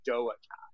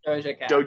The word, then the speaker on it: Doja: We're doing, is it Doja Cat or Doa Cat? How do you say her name? – I think it's Doja, right? – Doja Cat.